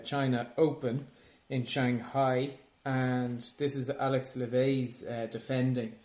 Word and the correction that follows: Levy: No, that's Levy. Levey was Thomas Levey. – Yeah, that's L-E-V-E-T. Yeah. This is L-E-V-Y.